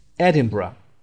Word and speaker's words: Edinburgh: Edinburgh نخونید این رو. Burg نیست. Edinburgh.